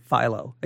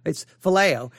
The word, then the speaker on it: phileo: I think I may have said philo. It's phileo.